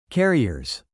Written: carriers

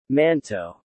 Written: manteau

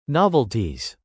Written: novelties

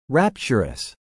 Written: rapturous

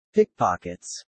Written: pickpockets

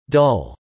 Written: dull

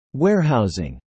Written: warehousing

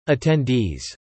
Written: attendees

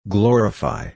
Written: glorify

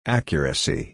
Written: accuracy

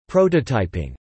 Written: prototyping

